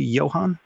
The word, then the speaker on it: Johan: Am I saying that correctly, Johan? – Yes, Johan, like John, but it's fine.